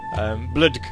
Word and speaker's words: BLDG: or Bludg, as it's pronounced there, because it's just B-L-D-G.